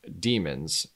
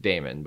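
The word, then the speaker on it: daemon: And so my thought here is that this was Charlize Theron's, what they call in those books in the movie, demons. It's called like Damon, but they pronounce demon.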